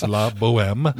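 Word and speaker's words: La Bohème: It's the perennial box office favorite, even though it seems to be one of the ones that are sometimes the hardest to spell or pronounce, but it's La Boheme.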